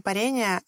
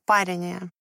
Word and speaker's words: парение: А звукорежиссер меня поправила, что нужно говорить не парение, а парение. Я возразила, что за 20 лет практики никогда и ни от кого не слышала вариант этого слова с ударением на первый слог.